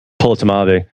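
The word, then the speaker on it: Polotmavé: For the amber, I always screw up the pronunciation, but Polotamave, which is a Polo and Tamave, which translation is half dark, which is basically amber.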